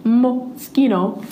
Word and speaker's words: Moschino: And I went home and looked it up and watched a pronunciation video, and it's moschino.